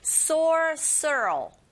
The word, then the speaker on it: sorcerer: Sorcerer. 발음이 조금 어려우시죠? R이 여러 번 들어가 있기 때문에 sorcerer 이렇게 발음하셔야 돼요.